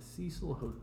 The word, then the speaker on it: Cecil Hotel: The Cecil Hotel. – Never heard of it. – That's how you say it. – Yeah, the Cecil Hotel.